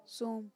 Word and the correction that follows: سوم: نہیں یہ دیکھیں سیووم کیا ہے سیووم ہوتا ہے نا تیسرا جو تیسرے کو کہتے ہیں سیووم